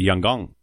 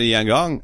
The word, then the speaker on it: Yangon: You said it so right. Your pronunciation was on. – Right. Yeah, Eric's right. – No, the chief's like, yeah, you got to fly to Yangon.